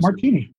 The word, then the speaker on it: Martigny: Where you have Martini and Martinez and Martinez and things that M-A-R-T-I-G-N-Y. Sound like. M-A-R-T-I-G-N-Y. – Right. All types of weird variations. – How do you pronounce that? Martini.